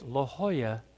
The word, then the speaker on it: La Jolla: He said, It's La Jolla. The J's are silent.